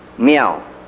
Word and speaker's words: Miao: We had a young man in Southeast Asia that we brought to this country in 1959 by the name of Sam Miao. Now that's spelled M-I-A-O, just like it sounds.